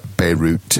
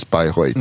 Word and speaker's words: Bayreuth: Bayreuth, B-A-Y-R-E-U-T-H. Normally pronounced like Beirut in English, but it's Bayreuth in German.